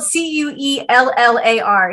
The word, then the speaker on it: Cuellar: His last name is spelled C U E L L A R. It's pronounced Cuellar, and he is the last pro-life Democrat in Get him out.